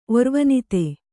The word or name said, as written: ಒರ್ವನಿತೆ